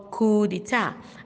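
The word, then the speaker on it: coup d'état: Now, coup spelled C-O-U-P, coup, C-O-U-P, coup. Now, d'etat, D apostrophe E-T-A-T, d'etat. You find out that the T there is silent and the P is also silent. So it is called coup d'etat.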